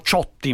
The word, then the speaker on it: Ciotti: Il presidente Ciotti, noi diremmo Ciotti, ma insomma sì. – Ciotti. – Sì, ma si può dire Ciotti perché a Nizza le pronunce sono, come dire, entrambi vanno bene.